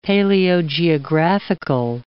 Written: paleogeographical

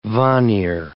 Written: Vanir

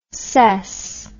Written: sess

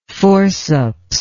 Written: forcipial

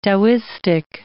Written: Taoistic

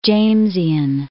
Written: Jamesian

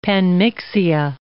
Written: panmixia

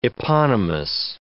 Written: eponymous

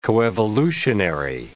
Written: coevolutionary

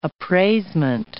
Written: appraisement

